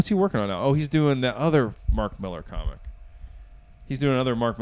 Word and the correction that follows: Mark Millar: Mark Millar? – Is that how you say his name? – Mm-hmm. – Fuck, Mark Millar.